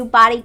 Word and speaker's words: BDY: This is a brand new body care brand from the makers of Tree Hut called Body. It's spelled B-D-Y,